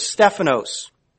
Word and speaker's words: Stephanos: It's the word Stephanos. It's spelled S-T-E-P-H-A-N-O-S, Stephanos.